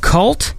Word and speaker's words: Kvlt: I believe the album is called Cult, although it's spelled K-V-L-T. Is that that cool thing where you make a V instead of a U?